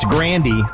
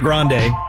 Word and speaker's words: Grande: It's Grandy, not Grande.